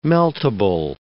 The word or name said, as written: meltable